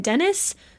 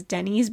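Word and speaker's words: Dennys: Her twin brothers, Sandy and Dennis, who as an adult I now assume is pronounced Dennis, but I always pronounced as a kid as Denny's because it's a Y, not an I, fit in, but maybe a little too well.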